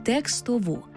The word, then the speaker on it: текстову: Так, пам'ятаймо й про правильний наголос – текстову.